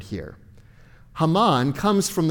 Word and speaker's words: Haman: We see that intentionality here with just his first name, Haman or Haman. That's probably how it was actually pronounced.